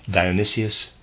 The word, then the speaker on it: Dionysius: Again, a reader's aside, I've been looking up that name, and it is pronounced and spelled only one way, not Dionysus as I have pronounced it before, so I will pronounce it Dionysius the Areopagite, end of aside.